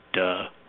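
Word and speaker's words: duh (德): They also all thought you had, when you were in that state, this power that unfortunately in modern Mandarin is pronounced duh, which my students always kind of laugh at when I say in class.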